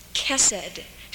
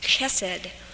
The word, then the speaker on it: chesed: And the first one is chesed, chesed. I didn't say that with a good German. It's spelled in English C-H-E-S-E-D.